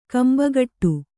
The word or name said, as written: ಕೊಂಬುಗೊಡು